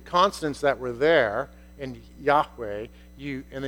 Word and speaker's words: Yahweh: The word Yahweh is close to how you say it in Hebrew. It used to be said Jehovah,